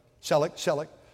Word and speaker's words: Selleck: Selleck.